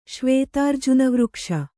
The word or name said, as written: ಶ್ವೇತಾರ್ಜುನವೃಕ್ಷ